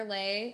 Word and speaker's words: Leih: I'm finishing up some things with my business, but you can follow me at Heather Lay. My last name is spelled L-E-I-H.